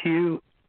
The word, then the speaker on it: Hugh: It's Hugh, H-U-G-H. Last name, Charlson, is spelled T like Tom. R-A-U-L-S-E-N like Nancy.